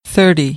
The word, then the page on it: thirty: American English; ɾ